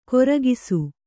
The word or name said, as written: ಕೋರಕಿಸು